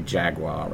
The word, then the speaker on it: Jaguar: We say Jaguar, but you say Jaguar, right? – Yes.